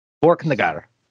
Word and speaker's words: Borknagar: Have I been saying that correctly? Borknagar. – That's with it. That's all good.